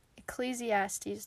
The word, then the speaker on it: Ecclesiastes: First of all, Ecclesiastes.